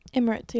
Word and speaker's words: Emirates: Emirates, yeah.